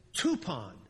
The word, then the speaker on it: tupon: The Greek word is tupon. Tupon rhymes with coupon. Okay? T-U-P-O-N, English spelling.